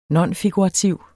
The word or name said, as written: nonfigurativ